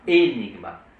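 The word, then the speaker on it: aenigma: Et Enigma. A-E se prononce toujours E.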